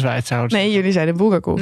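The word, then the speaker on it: Bulgakov (Булгаков): Eh, dus ik dacht, ik, ik, dat zal wel voor uh, Bulgakov ook gelden, zoals jullie dat eh, noemden. Dus heb ik mijn Russische oom opgebeld om te vragen hoe spreek je dit uit. En die zei Bulgakov.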